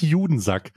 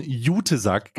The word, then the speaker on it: Jutesack: Nee, auch nicht Judensack, sondern Jutesack. Ganz, ganz wichtig in der Aussprache in diesem Zusammenhang.